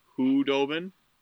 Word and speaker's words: Khudobin: For all you listeners, if you want to check out Anton Khudobin, that's how you pronounce it, actually.